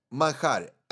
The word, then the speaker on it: manjar: Dulce de leche manjar. M-A-N-J. Because in Spanish, we pronounce J as A-R. Manjar.